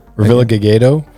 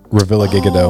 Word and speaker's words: Revillagigedo: How do you pronounce? – Revillagigedo or Revillagigedo. Oh, okay. I've heard them both. – Yeah. And then everybody just calls it Revilla. If you're from there, just say Revilla.